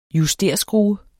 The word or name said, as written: justerskrue